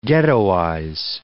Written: ghettoize

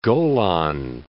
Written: Golan Heights

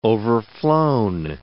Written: overflown

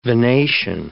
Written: venation

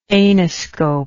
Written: anoscope

